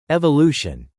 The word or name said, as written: evolution